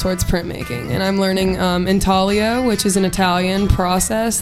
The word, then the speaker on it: intaglio: Intaglio, but the G is silent.